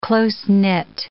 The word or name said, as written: close-knit